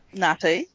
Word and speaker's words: Ngāti: It's N-G-A. There's like a little cuppy on the A. T-I. – Yeah. – What is that? Nati? – Nati.